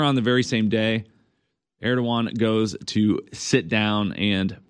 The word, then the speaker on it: Erdogan: I found a hilarious clip, actually, from about 10 years ago of Putin and, how do you pronounce the president of Turkey's name, Matt? I know it's spelled Erdogan. Erdogan? Is that right? Erdogan? – Correct.